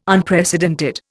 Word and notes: Unprecedented: Indian

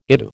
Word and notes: ITU: American English

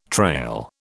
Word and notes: trail: British English

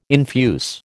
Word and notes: infuse: American English

